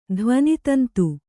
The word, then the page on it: ಧ್ವನಿತಂತು: dhvani tantu